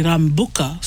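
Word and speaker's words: Rabuka: And lucky I already know the Prime Minister, Sitsiviri Rambuka. So, yes. – But the way it's spelled does not have the M.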